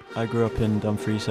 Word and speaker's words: Dumfries: And we're also going to go with one of his players here, who is a sophomore from Dumfries, Scotland. Did I say that right? – Yep, that was pretty good.